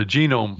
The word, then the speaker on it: Genome: But, and of course, news out of Pandora talking about launching this Gnomi. Is that the right way to pronounce that? The Gnomi project? Or did you read about that? – Oh, the genome. – Genome. Project. There we go.